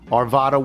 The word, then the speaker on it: Arvada: It is simple. Arvada, with the central A being flat, as in the word flat.